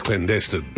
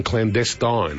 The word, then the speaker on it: clandestine: So that's, to me, the word is clandestine. – Mm. – Right. But I see a movement towards Catherine's pronunciation that people are now often saying clandestine.